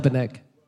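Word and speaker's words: Werbeniuk: So the snooker player, Bill Werbenyuk. How do you say that? – Werbenyuk.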